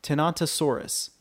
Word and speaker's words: Tenontosaurus: Tenontosaurus.